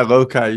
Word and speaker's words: Lokai: Yeah, Loci.